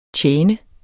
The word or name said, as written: tjene